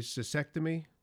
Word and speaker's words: cystectomy: You mentioned the cystectomy.